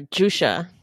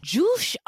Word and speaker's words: Jousha: Or Jusha. – Jusha. Oh, yeah. It's spelled J-O-U-S-H-A, at least on the website. And it may be a misspelling of Joshua.